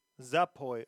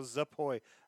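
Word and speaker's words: Zapoy (запой): I don't know if I'm saying it right at all, but Google, as per usual, Google Translate might be able to help me out. – Zapoy. – Okay, so Zapoy. I was saying it right, just getting confused.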